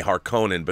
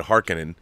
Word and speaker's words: Harkonnen: I used to say Harkonnen, but they said Harkonnen. So take your pronunciation, I guess. But in this book, they're the Harkonnens, not Harkonnens. I just always thought Harkonnen sounded more Star Trek-y, so that's what I've always said.